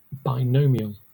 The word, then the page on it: binomial: Southern England; British English; /baɪˈnəʊ.mi.əl/